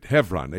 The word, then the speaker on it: Hebron: Now, you may hear me pronouncing it Hebron, H-E-V-R-O-N. You may spell it, and in the South, they have a bunch of cities named Hebron, H-E-B-R-O-N. But the B has a V sound in Hebrew, for that's the purpose for which that we are using that terminology.